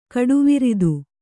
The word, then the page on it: ಕಟಿವೃತ್ತ: Kannada